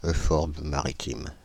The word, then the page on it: euphorbe maritime: France (Île-de-France); /ø.fɔʁb ma.ʁi.tim/